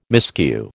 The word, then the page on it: miscue: American English